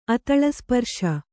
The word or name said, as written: ಅತಲಸ್ಪರ್ಶ